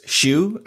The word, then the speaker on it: Schuh: Just look for the ServiceNow Magician, or you can just type in my name, Chris Schuh, and that last name is spelled S-C-H-U-H. One more time, that's S-C-H-U-H.